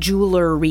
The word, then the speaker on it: jewelry: It's jewel, and there's no E-R-Y. It's just jewel-R-Y. – Yeah, exactly. – It's not jewelry. – Yeah. The pronunciation's pretty hard, even for native speakers. Mm-hmm. We say it just quickly, jewelry.